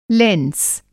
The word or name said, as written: लैंस